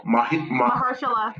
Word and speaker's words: Mahershala: Mahershala. – There you go. Okay, Black Queen. Know your Black King's name. Mahershala Ali is starring a new book called Green Book.